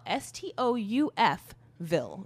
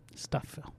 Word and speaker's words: Stouffville: They all so it's spelled Stouff. Ville, V-I-L-L-E. Mm-hmm. But everyone pronounces it Stouffville. – Which must be a Canadian thing. Because like, obviously in American English, that's Stouffville. – That middle F needs to be recognized, in my opinion.